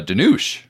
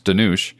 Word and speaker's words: Dhanush: He's like huge in India, Dhanush. I believe that's how it's pronounced, Dhanush.